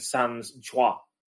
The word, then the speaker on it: sans joie: and sans joie. So the OI or OY is pronounced as a wa sound.